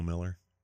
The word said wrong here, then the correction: Milker: Milker, not Miller.